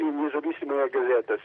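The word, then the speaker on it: Nezavisimaya Gazeta (Независимая газета): cerco di pronunciare al meglio, Nezavisimaya Gazeta, promossa? – Sì, Nezavisimaya Gazeta, sì, esattamente.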